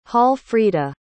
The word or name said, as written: hallfrita